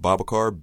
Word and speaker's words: Babacar: My name is spelled Babacar, B-A-B-A-C-A-R, last name Njai, N-D-I-A-Y-E.